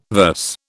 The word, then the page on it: verse: British English